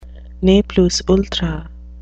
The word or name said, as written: ne plus ultra